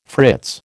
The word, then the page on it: fritz: American English